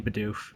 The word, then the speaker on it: Bidoof: Did I pronounce that right? Is it Bidoof? – Yes. Yes, all 12 days should be Bidoof.